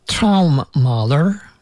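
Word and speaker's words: Traummaler: It was Traummahler, written together. I'm pronouncing it separately so that you can hear how it is said.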